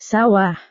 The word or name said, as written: sawah